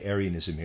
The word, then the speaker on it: Arianism: Readers aside, Arianism here, I'm going to pronounce it that way, is spelled, though, A-R-I-A-N-I-S-M, referring to Arius.